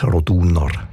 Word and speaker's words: Roduner: In dem Fall ist es aber eher die zweite Silbe, die betont Roduner.